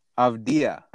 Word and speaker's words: Avdija: Avdija.